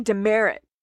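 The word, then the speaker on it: Demaret: Yes, Jimmy Demerit. Americans pronounce it Demerit.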